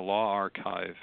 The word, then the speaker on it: LawArXiv: And so there's social sciences are starting to get into this area, and now Law Archive, as it's pronounced, LAWARXIV, is coming online, and it's going to be a law-specific open platform for distributing scholarship.